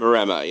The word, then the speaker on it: Maremma: Is it a Maremma? Is that how we're pronouncing it? – Maremma, yeah.